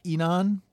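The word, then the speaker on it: Inan: This guy is it's Enon. – Okay. – Is that his name? – Yeah. – Or that's a pronunciation that you got? – Yeah. Mm-hmm.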